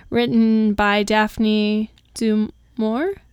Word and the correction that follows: du Maurier: Dumourier. – Yes, thank you.